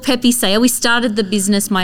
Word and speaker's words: Pepe Saya: So it's called Pepe Saya. – Pepe Saya.